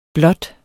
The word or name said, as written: blot